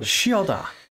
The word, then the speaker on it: Chioda: I wanted to go with a ch. But I was like, no, it's Chioda. – Chioda.